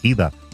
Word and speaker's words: Ida: Las tareas de rescate y el recuento de la devastación causada este domingo con la llegada a Estados Unidos del catastrófico huracán Ida, o Aida como lo pronuncian en inglés,